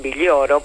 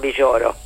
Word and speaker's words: Villoro: Ellos le dicen Villoro, por supuesto. Nosotros diríamos Juan Villoro. Se escribe con L.